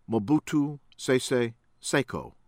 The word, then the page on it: Mobutu Sese Seko: American English; from Zaire